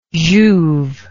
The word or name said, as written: jouve